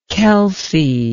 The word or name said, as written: Kelsey